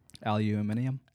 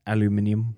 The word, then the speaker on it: aluminium: No, center is C-E-N-T-R-E. – Centre. – Centre. – Yeah. – I enjoy the British pronunciation of aluminum. – Aluminium. – Aluminium.